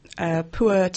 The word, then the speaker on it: pu'er: This is a pu'er tea from Yunnan in southern China. – How do we spell that? – P-U-E-R.